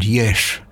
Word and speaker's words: Yesh: Yesh is the name, it is S-H at the end, Yesh. That's how it's spelt.